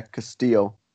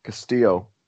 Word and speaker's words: Castillo: I'm going to stop you there, Castillo. – What? – Castillo. – What do you mean? – That's how you say it. – It's like Australia. – The L is silent.